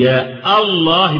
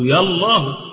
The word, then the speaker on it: الله: وهنا فيه لغتان، فيه نطقان في لفظ الجلالة، إما أن تنطق المنادى بهذا الشكل أو جلالة بهمزة قطع أو بهمزة وصل، والاثنين صح، يعني تقول يا الله بهمزة قطع أو يا الله يلا، زي ما هنقول في العامية يلا، هذا يعني في اللغة فصيحة، طبعا اللغة فصيحة، يعني العربية تجيز لك أن تنادي لفظ الجلالة إما بهمزة القطع وإما بهمزة الوصل.